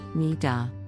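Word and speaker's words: niddah: This week's Jewish terminology word is nida, pronounced nida.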